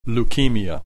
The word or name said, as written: leukemia